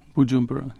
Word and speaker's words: Bujumbura: But he's beginning to hear a sort of, this is in the capital of Burundi, am I pronouncing it right, Bujumbura. – Bujumbura, yeah.